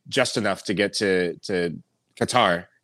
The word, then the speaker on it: Qatar: By the way, the correct way to pronounce Qatar apparently is Qatar. Qatar. It's like a soft G with a Qatar.